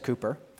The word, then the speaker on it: Cowper: William Cowper, it's spelled Cowper, but it said Cooper, was a British poet who lived from 1731 to 1800.